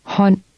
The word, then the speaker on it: hånd: In Danish, D is silent when it follows L, R, or N. Try to read word 6. – Hånd.